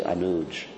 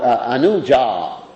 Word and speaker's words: Anuja: And sometimes in Hindi they just say Anuj, right? They take off the A. Hindi is sort of like a Sanskrit, not properly pronounced. And, it's interesting. Anyway, it's interesting that how they pronounce things. I have to always remember to take off the A when I'm saying something in Sanskrit and preaching to Hindi-speaking people. So, Anuja.